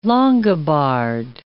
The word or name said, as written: Longobard